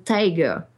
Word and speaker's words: TIGR: The Tiger. The Institute for Genomic Research. Donc ça se prononce exactement comme tigre en anglais, ça s'écrit T-I-G-R.